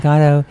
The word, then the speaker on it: Godot: And the waiting in Godot, sorry, watch my pronunciation, Godot.